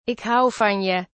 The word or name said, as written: ik hou van je